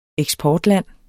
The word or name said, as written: eksportland